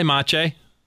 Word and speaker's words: Maciej: Hi, Maciej.